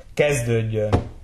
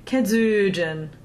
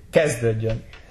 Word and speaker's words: kezdődjön: So the first stressed syllable is always the first one in the word. So we shouldn't stress that middle syllable like saying kezdődjön. – Would nobody understand it. – Right. – But you have to say kezdődjön, and it's understood.